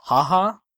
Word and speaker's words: Jauja: So my number two is a film called Ha Ha, which is spelled J-A-U-J-A.